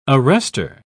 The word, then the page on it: arrestor: British English